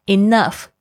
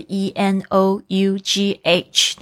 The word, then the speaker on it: enough: 好，这边呢，我要特别讲解一下这个 enough 这个字呢，这个拼法是 e n o u g h，但是它的发音有很多同学不能掌握，就是这个 enough，这个 o u 是发这个倒过来的，这个好像倒 v。